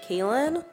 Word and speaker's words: Kaylin: So my Instagram is Therapy with Kaylin. Therapy, and then my name is spelled K-A-Y-L-I-N.